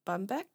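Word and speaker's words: Bombeck: I want to say her last name is pronounced Bumbeck, B-U-M-B-E-C-K.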